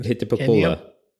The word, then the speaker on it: Hitapacolo: Could you do me a favor and try to pronounce that title that I just made? – Hitapacolo. – Oh, yeah, you got it. Yeah, that's how it's pronounced normally without the Spanish accent.